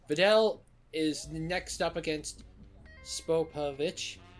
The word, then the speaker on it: Spopovich: Three syllables. That's three syllables. – Spopovich. – There you go.